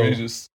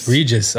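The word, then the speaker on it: Prograis: Yeah, so Regis. – Say it again? – Regis. – Okay, that's how you say his name.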